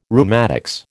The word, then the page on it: rheumatics: American English